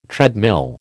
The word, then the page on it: treadmill: American English